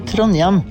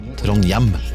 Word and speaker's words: Trondheim: I Trondheim sier vi Trondheim. – Trondheim.